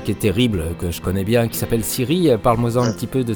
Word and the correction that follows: Cyril: Alors je crois que c'était prononcé Cyril. – Ah, c'est Cyril, oui, pardon. – Ouais, il y a deux L E, mais c'est Cyril.